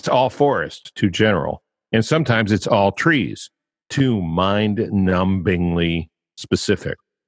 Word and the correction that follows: numbingly: I think I shouldn't have pronounced the B in numb, right? Mind-numbingly specific.